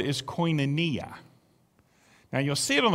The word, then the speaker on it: koinonia: Koinonia. The W, what looks like a W, is pronounced like an O. So it's koinonia.